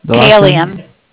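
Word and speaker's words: Kailian: Kylian.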